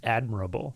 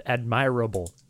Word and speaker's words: admirable: The word is pronounced admirable. – What did I say? – You said admirable. – Admirable. I'm Canadian, so. – Jesse. – Yeah, that's Canadian pronunciation.